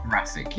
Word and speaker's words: Jurasik: uh, guest starring Sally Faulkner, India Fisher, and Peter Jurasik. Is that how you say his name? Jurassic. – Jurassic, yeah, like like Jurassic Park.